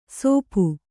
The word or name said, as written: ಸೋಪು